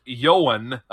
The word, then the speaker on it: Ioan: Yeah, so that I O A N is pronounced Yoan apparently,